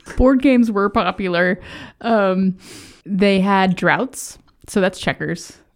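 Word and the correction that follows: draughts: They called it droughts. – Drafts. – Jots. – Drafts. – Drafts? – Drafts. – Okay. – Like a draft beer. – It's spelled D-R-A-U-G-H-T? – Drafts.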